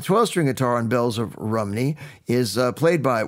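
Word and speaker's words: Rhymney: But oddly enough, Bells of Rimini, which is actually pronounced Rumney, forgive me, my Welsh friends, there's no such town as Rimini, it's Rumney.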